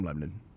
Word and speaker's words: Lebanon: I'm from Lebanon. I say it right.